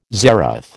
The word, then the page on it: zeroth: American English